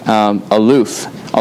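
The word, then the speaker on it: Aloof (אַלּוּף): Aloof, although it's not like our English word aloof. That's just how it's pronounced.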